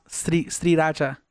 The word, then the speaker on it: Sriracha: You know, it's actually pronounced Sriracha.